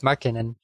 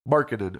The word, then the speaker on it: Markkanen: Mark, is it Laurie Markkanen? Yeah, but it's Markkanen. – Markkanen. Okay, we've gotten that one right.